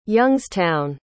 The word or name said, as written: Youngstown